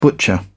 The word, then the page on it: butcher: British English